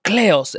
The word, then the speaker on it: Kleos: she wanted Kleos, or Kleos as you would actually pronounce it in Greek.